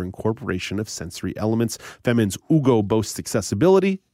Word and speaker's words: Ugo: But if you are interested in learning more about Ugo, it's spelled U-G-O, Ugo. And the designer is Famine, so F-A-M-I-N. But Ugo, U-G-O, is where you can learn more about this tactile stove top.